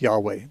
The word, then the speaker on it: Yahweh: Jehovah, or actually Jehovah is not really a correct pronunciation, more like Yahweh.